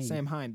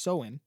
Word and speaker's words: Samhain: Samhain. That's not correct. It's pronounced Soin. So there's a festival of Soin.